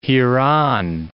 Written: hereon

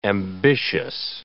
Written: ambitious

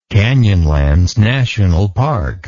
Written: Canyonlands National Park

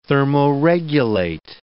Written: thermoregulate

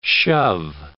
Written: shove

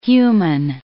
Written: human

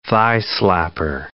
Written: thigh-slapper

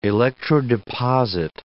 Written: electrodeposit

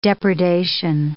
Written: depredation

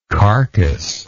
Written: carcassless

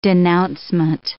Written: denouncement

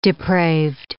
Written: depraved

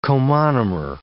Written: comonomer

